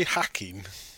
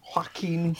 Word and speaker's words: Joaquin: Did you say Hacking? – Joaquin.